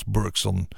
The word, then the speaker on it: Bergson: Onthoud zijn naam dus, Chris Bergson. Of in New York zal het wel als Bergson worden uitgesproken.